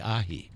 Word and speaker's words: ahi: And I think I've heard it pronounced ahi ahi before.